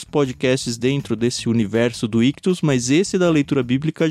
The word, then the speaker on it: Ichthus: Lembrando que Ictus se escreve I-C-H-T-H-U-S.